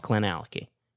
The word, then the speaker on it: Glenallachie: Yep, Glenallachie. That's okay. How you pronounce it. – Okay, Glenallachie. – Right? Glenallachie. Okay.